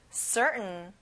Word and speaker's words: certain: 확실한, 틀림없는, certain. 가운데 T 발음은 안 나요. Certain.